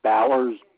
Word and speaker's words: Bowers: Dave, D-A-V-E, Bowers, B-O-W-E-R-S. – Could you spell that last name for me? – B-O-W-E-R-S.